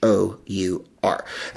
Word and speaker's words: Freshour: It's spelled Fresh Hour. So it's Shannon Freshour.